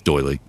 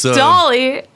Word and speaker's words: doily: It's doily. I can't read. – It's doily.